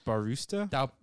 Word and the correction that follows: Bautista: Bautista. – Barusta. – I like Barusta. – And there's no R in there.